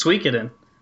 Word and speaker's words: Suikoden: And my other question for you, since you love the series so much, do you know how to actually pronounce the name? – I thought that's how you said it. – No. I believe it's Suikoden.